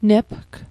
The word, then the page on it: nepg: Mi'gmaq